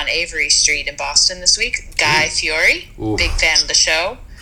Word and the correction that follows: Fieri: Fieri. Fieri. Fieri is the traditional Italian pronunciation.